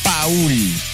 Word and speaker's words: Paul: Se escribe Paul, P-A-U-L, Paul.